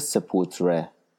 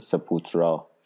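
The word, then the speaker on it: Sepultura: آهنگ اولی که میخوام برای تو پخش کنم از گروه برزیلی سپوتره که احتمالاً شما با تلفظ سپوترا شنیدنش، به زبان پرتغالی اسمشون و معنی قبر.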